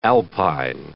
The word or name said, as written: alpine